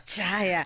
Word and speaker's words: Jaya: Is that how I say it? – You said it right. – I'm so glad because it's always like Gaia, Jaya.